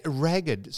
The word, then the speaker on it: ragged: So ragged. – So that is a commonly mispronounced word in that poem, and you mispronounced it yourself. – I did. – It's ragged.